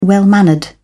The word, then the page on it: well-mannered: British English